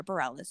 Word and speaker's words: Bareilles: Am I saying her name right? You are now. That's been a battle of years. But Bareilles was where I think you started. Barry Ellis. I mean, you were butchering it.